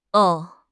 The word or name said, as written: o5